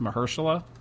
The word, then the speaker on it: Mahershala: It's just how it sounds. I think it's actually pronounced Mahershala, but yeah, playing Blade.